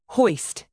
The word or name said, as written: hoist